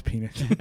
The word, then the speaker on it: Penix: So, I think it's pronounced Penix. – No, it's Penix.